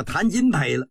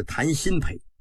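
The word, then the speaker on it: 谭鑫培: 今儿个是谈新陪唱的文昭关，他不认识新字，把这个新字啊念成金了，叫谈金陪了。光绪微微的一笑，是谈新陪，不是谈金陪。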